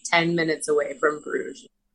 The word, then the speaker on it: Bruges: Is it just Bruges? Is that how you pronounce it? – Yeah.